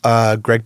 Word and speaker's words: Greg: You can find me on Facebook, of course, or Yelp, Greg Beckett, Motivational Hypnotist. – And that's spelled G-R-E-G for Greg and B-E-C-K-E-T-T.